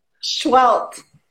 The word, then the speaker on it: Seheult: Is that how you say your last name? – Schwelt. – Schwelt. Okay, that was the last thing I was going to ask you before we went on. Schwelt, got it.